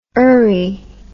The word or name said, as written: ery